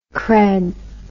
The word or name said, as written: cred